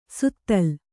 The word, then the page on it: ಸುತ್ತಲ್: kannada